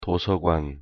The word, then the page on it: 도서관: Korean